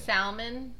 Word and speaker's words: salmon: No, I don't think you say the L. No, you don't say the L. – Okay. I can't pronounce it the other way. That's what I'm saying. – Salmon.